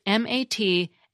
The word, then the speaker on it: materiel: Materiel is indeed a word of its own, but it's a direct borrowing from French. It's spelled with an E instead of an A near the end, and often has an accent mark above the first E. So it's MATRIEL.